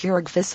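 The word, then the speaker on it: Jurgis: And she had just been married and married to Georgius, pronounced Georgius of all men,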